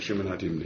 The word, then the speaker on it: Shimon HaTimni: Rashi says over here it's Minya Timna, which means we'd have to pronounce it Shimon HaTimni.